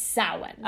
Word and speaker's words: Samhain: I'm pretty sure it's Samhain.